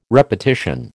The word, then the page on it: repetition: American English